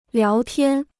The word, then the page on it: 聊天: liáo tiān